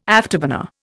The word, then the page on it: afterburner: Indian